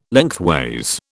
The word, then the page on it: lengthways: British English